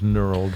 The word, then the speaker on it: knurled: Knurled? – Yes.